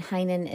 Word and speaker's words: Heynen: And Heinen is spelled H-E-Y-N-E-N.